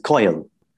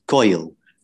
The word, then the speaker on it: koyal: So she says koyal. And those two syllables, koyal, really reflect the bird's call because that's what it's named after, its call.